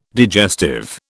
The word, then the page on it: digestive: British English